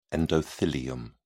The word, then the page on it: endothelium: British English